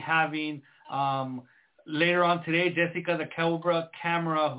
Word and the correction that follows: Camara: who Camara, I don't know how to pronounce it, Lupe, you might be able to help me with that. – Camara.